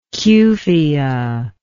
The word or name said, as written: cuphea